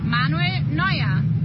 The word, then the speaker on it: Manuel Neuer: Manuel Neuer.